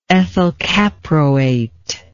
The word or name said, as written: ethylcaproate